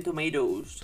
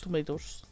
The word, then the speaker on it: Tomatoes: Y en Rotten eh, Tomatoes, en Tomatos, no sé cómo se pronuncia esto. En... – Rotten Tomatoes. Tomatoes.